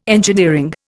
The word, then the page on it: engineering: Indian